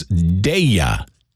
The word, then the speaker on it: Daya: Having a little trouble figuring out the pronunciation of her name, it's Daya.